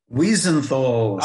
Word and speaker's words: Wiesenthal: I always say this incorrectly, so I want you to say it, the Simon Wiesenthal. – Oh, I get it. Okay, the Simon Wiesenthal Center.